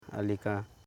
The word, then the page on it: alika: a.li.ka